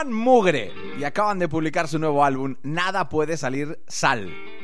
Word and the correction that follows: Nada Puede Malir Sal: No, lo he dicho mal yo. Es Nada Puede Malir Sal.